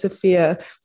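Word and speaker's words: Efia: Yes, it's Afia. You know, for a time, I actually got confused how to pronounce my own name because most of my friends are Scottish. So they would say Afia. And I was like, oh yeah, Afia.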